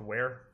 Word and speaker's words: Weare: Uh, I'm from New Hampshire originally, a small town called Ware. Called where? Yes, this is This is exactly how my first conversation with everyone goes. Um, it's spelled W E A R E, and it's uh, oh, okay.